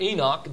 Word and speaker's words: Enoch: One of the best testimonies of a man in the Word of God is that of Enoch, or Enoch. It depends on how you want to pronounce it.